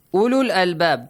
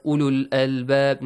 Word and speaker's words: ulul albab: Don't pronounce the wall. It means do not prolong the sound. Just read it. Oh, not oo. Ulul albab, ulul albab. Not long vowel. Not ulul albab. No, ulul albab.